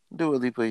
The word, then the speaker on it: Dua Lipa: Is that her name? – Dua Lipa? – Is that her name? Is that how you say it? – Dua Lipa, yeah. D-U-A. L-I-P-A.